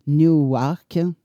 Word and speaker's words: Newark: Alors, euh, il est du côté de Newark, il paraît que ça se prononce comme ça.